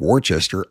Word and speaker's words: Worcester: And I know the people from the Bay State like to mess with me with pronunciations, but even though this one looks like Worcester, I know it's pronounced Worcester.